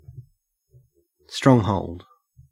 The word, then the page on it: stronghold: Australian English